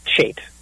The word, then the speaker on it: Chait: But uh, it's actually pronounced Chait.